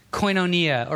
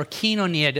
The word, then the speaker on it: koinonia: Now, in the New Testament, the English word that you encounter, fellowship, is a translation of a Greek word, koinonia, or kinonia, depending on which translation, or what's the right word? Pronunciation scheme you subscribe to.